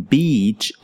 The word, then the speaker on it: beach: The sounds we have here, according to the International Phonetic Alphabet, are E for beach and I for bitch. So, as you can see and hear, there really is a difference when pronouncing beach and bitch.